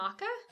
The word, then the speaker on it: maca: Like M-A-C-A? – Yeah, not matcha. – No. – Tea. – Yeah, okay. Different. – I was like, have I been pronouncing that wrong? – No, you have not.